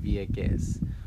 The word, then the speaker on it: Vieques: Um, I am still in Vieque, as they like to say, because in Puerto Rico they do not use the S's in Spanish. So, in most cases, it's getting dropped. It's spelled Vieques, but you would pronounce it Vieque.